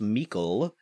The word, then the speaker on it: Meikle: I have a question now from Ross Meikle, rhymes with treacle, for Baby David.